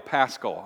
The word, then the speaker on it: Paschal: P-A-S-C-H-A-L, Paschal. I've been pronouncing it wrong. Paschal.